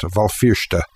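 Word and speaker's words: Béal Feirste: The written BH is pronounced V, and the S is sibilant, as in the Irish for Belfast, Valfirsta.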